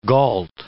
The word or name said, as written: gault